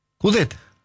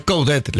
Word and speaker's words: Coudet: Coudet. – Por eso, se pronuncia la T. – Sí. Nosotros le decimos Chacho, ¿no? – Bueno. – Entre nosotros. – A los amigos. – Pero es Coudet la pronunciación.